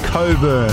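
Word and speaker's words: Cockburn: It's why it's spelled. It's pronounced Bruce Coburn.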